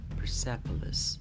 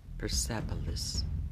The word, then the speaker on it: Persepolis: تلفظ صحیح این کلمه در انگلیسی به این شکل هستش پرسپلس پرسپلس Persepolis